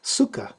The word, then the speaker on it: Sukha: Sukha rhymes with dukkha, but not emphasized nearly as much.